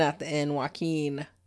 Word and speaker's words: Joaquin: Why do you always say his name? Joaquin. – Joaquin. – It's Joaquin Phoenix. It's not hard.